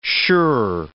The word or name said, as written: sure